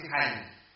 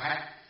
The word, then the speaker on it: hạnh: Tà hạnh này, cái này là chữ bình thường chúng ta đọc là chữ hành, nhưng ở trong bài này phải đọc là hạnh.